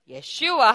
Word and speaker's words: Yeshua: But especially when we're talking about the name, we want to be saying it correctly in the original pronunciation that is meaningful, which is what? Yeshua, hallelujah.